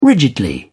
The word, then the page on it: rigidly: American English